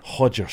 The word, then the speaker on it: Roger: I think that you find it's pronounced Hodger. – Hodger.